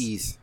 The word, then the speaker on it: Ys: And 1992, NEC comes up with Turbo Duo, and it comes with a bunch of games, actually, that do you know how to pronounce that? YS? – It's East. – I think it's an RPG. – It's East, I believe.